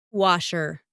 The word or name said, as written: washer